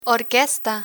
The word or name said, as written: orquesta